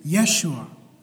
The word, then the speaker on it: Yeshua: The name Jesus, or as it is pronounced in Hebrew, Yeshua.